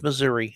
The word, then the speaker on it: Missouri: Some people pronounce it Missouri. There's no A at the end. It's Missouri, okay? It's English. It's not hard, Missouri.